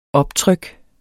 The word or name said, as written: optryk